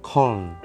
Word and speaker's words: Köln: dan Köln ejaannya ini KUHLN jadi kalau bacanya Köln bersama Mas Miroto,